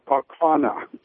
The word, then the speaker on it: Kaukauna: How do you say that? Thanks for listening to WCPT. – Oh, that'd be Kaukauna.